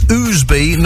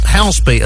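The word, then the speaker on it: Ousby: And it's Oosby, not Houseby, as some people say.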